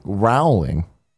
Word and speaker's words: Rowling: Rowling. – I would say actually Rowling. It's J.K.